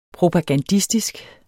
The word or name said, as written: propagandistisk